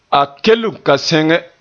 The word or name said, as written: kelen